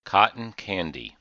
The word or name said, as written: cotton candy